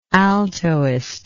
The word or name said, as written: altoist